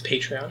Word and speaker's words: Patreon: Is it Patreon? I've been saying it wrong this whole time. – It's Patreon.